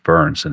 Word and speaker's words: Byrns: You know, my last name is pronounced Byrns, and here I am, a Byrns survivor.